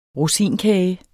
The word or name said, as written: rosinkage